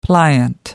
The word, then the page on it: pliant: American English